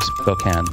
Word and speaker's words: Spokane: That was, by the way, that was the correct pronunciation of Spokane.